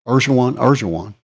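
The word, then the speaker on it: Erdoğan: And the president there, Erdogan, how do you say his name? – Erdogan.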